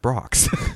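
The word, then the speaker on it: Brach's: Okay, so I just found something out recently. It's pronounced Brox.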